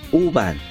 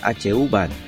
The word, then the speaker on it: Huban: Esta banda es de Mongolia y tocan heavy metal y rock, duro, pesado se llaman Uban, se escribe H-Uban, también se puede decir Huban.